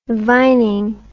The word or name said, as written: vining